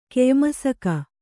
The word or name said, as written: ಕೆಯ್ಮಸಕ